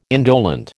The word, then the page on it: indolent: American English